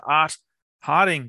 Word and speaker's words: Art Harding: So I'd love to welcome to the podcast Mr. Art Harding.